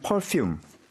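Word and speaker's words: Perfume: 네. 영어로 하면 퍼퓸. – 아, 일본식 발음은 이거죠. 예.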